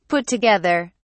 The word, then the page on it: put together: British English